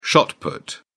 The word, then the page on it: shot put: British English